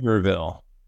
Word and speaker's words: Sevierville: Sevierville.